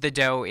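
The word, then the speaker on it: Dø: It is pronounced The Doe. It is spelled D-O, and the O has kind of a slash through it.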